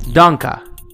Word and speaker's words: Danke: Danka. D-A-N-K-E. Danka. Question 2. Toda. T-O-D-A.